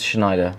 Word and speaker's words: Schneider: This that is a very well-known article website, news website within the industry, and is about, I'm not sure on the pronunciation of this one, Schneider? – It's Schneider.